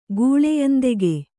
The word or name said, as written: ಗೂಳೆಯಂದೆಗೆ